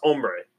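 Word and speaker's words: hombre: There's an H in there. – It's an H. – It's pronounced ombre. – Yeah. The H is silent.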